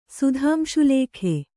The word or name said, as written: ಸುಧಾಂಶುಲೇಖೆ